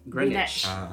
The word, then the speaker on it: Greenwich: Greenwich.